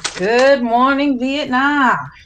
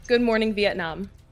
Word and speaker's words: Good Morning, Vietnam: Good morning, Vietnam. – That's the right way to say that title, too. – Good morning, Vietnam.